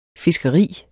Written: fiskeri

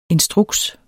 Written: instruks

